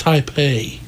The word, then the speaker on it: Taipei: And all of a sudden he goes, I said, it's pronounced Taipei.